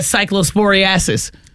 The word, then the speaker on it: cyclosporiasis: That cyclosporiasis. – That's how you say it. – Ain't it cyclosporiasis? – Yeah, I think so.